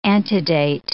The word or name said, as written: antedate